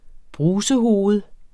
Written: brusehoved

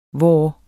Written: vor

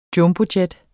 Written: Jumbojet

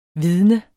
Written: vidne

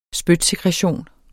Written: spytsekretion

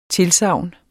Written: TILSAGNS

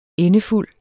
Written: endefuld

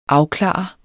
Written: afklare

